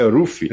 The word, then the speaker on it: Luffy: E assim, quando a gente lançou na Conrad, a nossa tradutora Drixada, ela falou assim, ó, a pronúncia é exatamente como a Pá fez. É, é Ruffy. Ruffy.